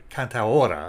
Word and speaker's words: cantaora: The performance that we're going to hear keeps that tradition of the cantoroa, I guess is how you, cantaora, I guess is how you pronounce it,